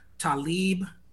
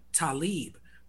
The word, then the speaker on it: Talib: So if you listen to my early records, like early Mood records, early Raucous Shit, pre-Black Star, you hear me saying Talib. But then when I got with Most, who's a Muslim, who does it more, he pronounces it more Talib, Most started calling me Talib on those Black Star records.